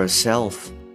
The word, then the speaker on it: herself: はい、そしてですね、えー、 for herself の部分なんですけれども、あの H がですね、かなりまあ弱く発音されているので、 for herself, for herself というような感じに聞こえてるかもしれないです。